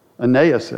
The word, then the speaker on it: Ananias: He turns your attention, Ananias' attention, Ananias, I guess is the correct way to say it, his attention back to Jesus.